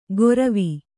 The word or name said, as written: ಗೊರವಿ